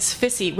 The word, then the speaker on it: SFSI: And San Francisco Sex Information, which the short acronym for is SFSI, and you'll hear us calling it SFISI, which rhymes with sissy.